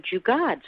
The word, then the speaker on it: jugaad: Can you explain, and first perhaps pronounce that word, and how's that defined? – Sure. So the word is jugaad.